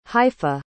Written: haifa

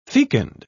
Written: fecund

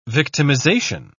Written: victimization

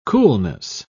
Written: coolness

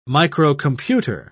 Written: microcomputer

